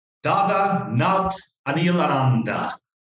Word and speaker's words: Dada Nabhaniilananda: And today we got a special guest with us, Dada Nabhaniilananda.